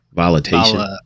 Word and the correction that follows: Volition: I was just reading that because that's the ones they did at least Saints Row 4. – Volition. That's what it is. I believe that's how you say it, right?